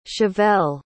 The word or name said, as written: chevell